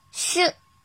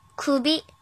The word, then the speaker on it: 首: 音读的时候读作“ 嘘”，“ 嘘”，“ 嘘”。比如“ 守卫”，“ 嘘”，“ 嘘”，“ 嘘”。写成日语汉字的时候，“ 手” 加位置的“ 位”，守卫。训读的时候读作“ 可比”，“ 可比”，“ 可比”。